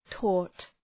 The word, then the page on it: taught: tɔ:t